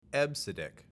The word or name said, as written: EBCDIC